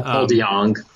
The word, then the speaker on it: DeJong: Do you know how you pronounce it? Paul DeJong? – Paul DeJong.